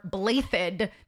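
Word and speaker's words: Blaiddyd: The Blue Lions, which is led by Dimitri Alexander Blathid. That's how they pronounce it in English. Like the two D's is pronounced as a TH for some reason.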